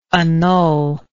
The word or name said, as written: annullable